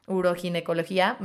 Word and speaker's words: uroginecología: como la Sociedad Mexicana de Ureginecología, uroginecología, madre mía, imagínense, o sea, yo jamás me podría graduar de ahí, no lo puedo ni pronunciar.